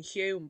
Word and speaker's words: Hulme: I think it's in Hume, but it's spelt HULME, Holm. So I was like asking everyone where Holm was, and they were all just looking at me as if to say, "What are you talking about? Hume or something?"